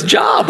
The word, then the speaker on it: Job: In fact, when you read it in the Bible, you think it says Job. Because it's spelled J-O-B. What are you doing? Oh, I'm reading about this Job over here. I don't know. I need a job. I thought I'd start there. It's actually pronounced Job.